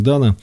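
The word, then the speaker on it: Dano: Это фильм Пола Дана, только что узнал, что его фамилию нужно произносить как Дана.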